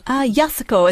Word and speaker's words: Yasuko: Yasuko is it's actually like Maori, in that the syllables are evenly distributed.